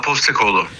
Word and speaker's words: Postekoğlu: Postekoğlu diye okunuyor değil mi? Öyle söyleniyor, telaffuz ediliyor. – Evet abi, Postekoğlu.